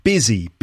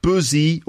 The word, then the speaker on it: busy: Busy, busy, busy. Busy like business. Business, busy. So it's not buzzy or buzzy, despite the U. It's actually busy. It sounds like it's got an I in it.